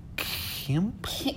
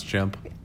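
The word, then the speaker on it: Mailchimp: Mail Kimp? – Kimp? How do you say it? – Kimp? I refuse to believe that someone doesn't know how to pronounce chimp.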